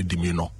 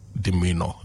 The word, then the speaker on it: demeanor: D-E-M-E-A-N-O-R. Some people try to say demeanor because of the O-N-O-R, right? But it's not demeanor, it's demeanor. And it refers to someone's outward manner and behavior towards others.